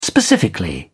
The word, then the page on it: specifically: British English